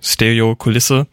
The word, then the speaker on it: Stereo Kulisse: Stereo Kulisse, I guess it's a German, or like it's a Swiss band, so I guess the pronunciation is should be in German, so Stereo Kulisse.